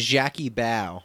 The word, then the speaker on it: Jackie Bao: I swear they're trying to say this lady's name is Jackie Bao. – Jackie Bao, like Jackie Brown?